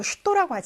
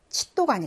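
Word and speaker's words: 슈또라고 (что): 이제 특수 발음인데 무엇이라고 러시아로 슈또라고 하잖아요. 칫도가 아니라 아까 알파벳에서 이것이 츄 발음 난다고 배웠는데 최가 샤 발음 나는 게몇개 있는데 그 중에 하나가 무엇이에요?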